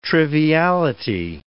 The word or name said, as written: triviality